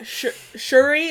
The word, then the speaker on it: Shuri: Yeah, Shuri, is that how you say her name? – Yep.